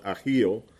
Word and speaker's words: Ahio: actually that's pronounced Ahio, the sons of Abinadab, drove the new cart.